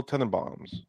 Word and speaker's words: Tenenbaums: Number 20, The Royal Tenenbaums. Did I pronounce that right? I think I did. – Yeah, you did.